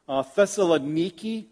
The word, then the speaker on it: Thessaloniki: Thessaloniki, I think it's pronounced today, in modern Macedonia.